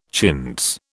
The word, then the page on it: chintz: British English